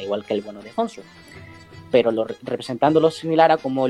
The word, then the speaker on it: Khonsu: Pero, por ejemplo, a Khonsu, que se pronuncia Khonsu, pero los actores de doblaje en español lo llamaron Khonsu, o sea, como es fonéticamente, como es por escrito, pero se pronunciaría Khonsu en el egipcio antiguo.